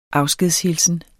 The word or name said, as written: afskedshilsen